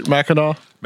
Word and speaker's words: Mackinac: I said that right, Mackinac? – Mackinac. – Yeah. – Mackinac. – Yeah, I didn't know that either because I kept looking at it and it looks like it should be pronounced Mackinac because it has a C at the end. But that's an Indian word.